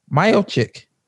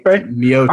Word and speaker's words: Miocic: Miocic. – Oh, no. Stipe. Miocic. I called him Steve every time. Miocic.